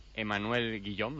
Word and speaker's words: Emmanuel Guillaume: Con Hotel, el director escénico de Cirque Eloís, Emmanuel Guillaume, no sé si lo digo bien. – Sí, está bien.